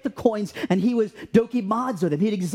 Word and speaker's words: Dokimazo: Dokimazo is how you pronounce it.